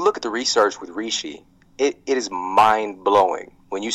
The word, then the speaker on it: reishi: Like today I had cordyceps, dual extracted cordyceps, or maybe I have lion's mane or reishi or chaga.